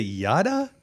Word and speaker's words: Yada: So first, does anyone actually know how to pronounce YADA? Is it Yada? – Yada. – It's Yada.